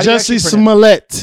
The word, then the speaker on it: Jussie Smollett: How Jussie Smollett.